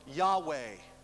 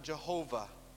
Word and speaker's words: Yahweh: He is, or Yahweh. Sometimes pronounced Jehovah. When you put in different vowels, you can pronounce it Jehovah. That's Yahweh. Rendered in the NIV, L-O-R-D in capitals.